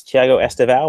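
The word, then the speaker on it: Thiago Estevão: That's Thiago Estevao. Am I saying that right, Thiago? – Yeah, you're fine. You're fine, buddy. Like, most people can't pronounce it anyway, so I just run with it.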